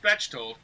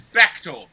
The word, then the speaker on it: Bechtold: Just like my name is spelled, it's not pronounced Bechtold. It's not pronounced Bechtold, like the guy who called my house the other day who wanted to pronounce it. It's Bechtold, like Bechtold, you a story.